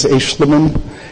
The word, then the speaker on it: Aeschliman: My last name, the pronunciation is Aeschlimann, and the spelling is A-E-S-C-H-L-I-M-A-N.